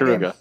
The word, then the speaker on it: Ikaruga: Roberto said he just got Ikaruga. Is that how you pronounce yeah, that? Ikaruga.